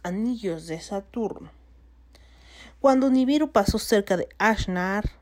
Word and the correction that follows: Anshar: Ashar, ah, no, Anshar, Anshar, qué complicado es pronunciar esto.